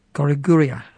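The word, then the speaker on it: Goraguer: Goriguia, as it's pronounced.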